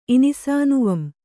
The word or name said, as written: ಇನಿಸಾನುವಂ